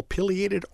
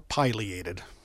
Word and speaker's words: pileated: I finally saw my first sighting this year of the pileated woodpecker, if I'm pronouncing that properly. Mm-hmm. – Both are acceptable, pileated or pileated.